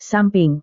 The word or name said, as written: samping